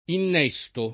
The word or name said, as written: innesto